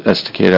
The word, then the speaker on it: Estacada: Yeah, it's Estacada. I was there yesterday. – I think I pronounced that properly.